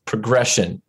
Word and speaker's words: Progrexion: I'm your host for today, and I'm speaking with Dr. Ken Knapton, Chief Information Officer of Progrexion. Uh, it sounds like Progression, it's spelled P R O G R E X I O N, just to be clear.